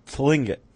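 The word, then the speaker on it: Tlingit: And many members of the, was that Lingit? Is that how it's pronounced? The Lingit tribe? – Lingit. – I think the T might be silent.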